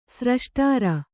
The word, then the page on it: ಸ್ರಷ್ಟಾರ: sraṣṭāra